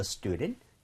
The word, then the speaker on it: student: Student 해서 U에다 좀 강세를 많이 찍어서요. 이게 앞쪽에 강세가 많이 가다 보니까 D라는 스펠링 부분이 굉장히 좀 뭐라 그럴까, 부드러워지죠? 그래서 student 이렇게 한다기보다는 student처럼 힘이 많이 빠질 겁니다.